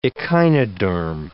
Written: echinoderm

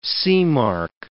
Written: seamark

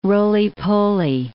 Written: roly-poly

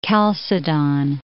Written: Chalcedon

